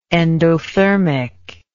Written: endothermism

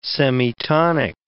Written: semitonic

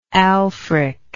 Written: aelfric

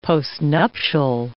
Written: postnuptial

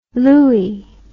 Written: louey

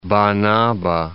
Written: Banaba

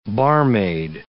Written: barmaid

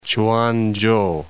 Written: Chuanchow

